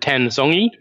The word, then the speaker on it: Tan Zongyi: Zhu Wenjun defeated Tan Zongyi by the narrowest possible margin, if it didn't go to tiebreaks.